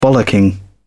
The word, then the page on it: bollocking: British English